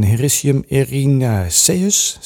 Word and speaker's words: Hericium erinaceus: En lion's mane is een Hericium erinaceus. Zeg ik dat goed? Ja, dat zeg, je, dat zeg je bijna, bijna, bijna, goed, bijna helemaal goed.